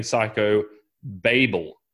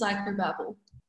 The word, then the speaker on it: Psychobabel: I've got to get that right because I've been saying Psychobabel, but apparently I've been wrong. Can you correct my pronunciation, please? – It is Psychobabel.